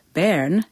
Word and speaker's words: Bern: A predator that isn't having much of an effect on prey these days is the bear, the symbol of Bern, which is pronounced Bern in German.